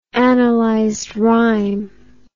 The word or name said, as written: analyzed rhyme